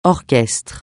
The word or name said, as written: orchestre